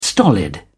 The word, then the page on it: stolid: British English